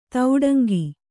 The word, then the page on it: ತೌಡಂಗಿ: tauḍaŋgi